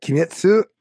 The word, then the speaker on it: Kimetsu: But as for today's series review, Demon Slayer, Kimitsu no Yaiba. I think I said it wrong and you took Japanese in college. what? Kimetsu no Yaiba. Yeah, what? I think I said Yaiba wrong.